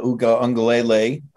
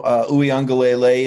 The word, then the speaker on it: Uiagalelei: Obviously DJ Uiagalelei, I got to throw the extra Lele in there. I had to listen to pronounce it myself. He'd been DJU to me this entire offseason. But, you know, Uiagalelei is a guy that, you know, he's large, statuesque, certainly looks like an NFL prototype, athletic.